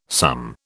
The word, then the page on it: some: British English